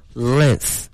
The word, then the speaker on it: length: 首先我们把这个 long 名词形式给大家说一下，它的发音是 length，它的拼写是 l-e-n-g-t-h。